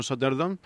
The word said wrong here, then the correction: Sottendorff: y al final lo he dicho mal, ¿te has dado cuenta? Con lo bien que iba. Los delirios de Von Sottendorf y su mente cuadriculada.